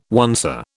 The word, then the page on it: oncer: British English